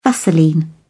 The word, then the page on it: vaseline: British English